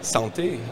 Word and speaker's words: Santé: Santé. – Well, how do you say it? – Santé. – Santé. – As we say in France.